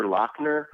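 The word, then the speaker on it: Laughner: This you had on your Instagram a Peter Lafner box set. – Yeah, it's actually, which I just learned, his name is pronounced Peter Lochner.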